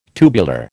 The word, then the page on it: tubular: American English